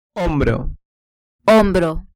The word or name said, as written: hombro